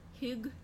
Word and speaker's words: Hygge: Hygge. There we go. That's how you say it.